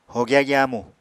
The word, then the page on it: hogya gyamu: apatani